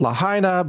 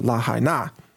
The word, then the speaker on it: Lahaina: They said, well, we've just received a very large order of our entire inventory to send them to Lahaina in Maui, which I know most Americans pronounce it Lahaina, but I've talked about the pronunciation before. I've come to the conclusion that the locals pronounce it Lahaina, or I should say the Hawaiians pronounce it, the Polynesian ethnic groups pronounce it Lahaina.